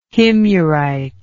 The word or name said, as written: himyarite